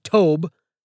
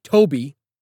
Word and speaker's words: Tobe: He pronounces it Tobe, but it's really Toby.